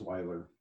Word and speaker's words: Weiler: Weiler, is that correct? – No, it's Weiler.